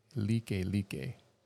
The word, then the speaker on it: Likelike: It's pronounced Like Like, but it looks like the word like, L-I-K-E, L-I-K-E, all one word.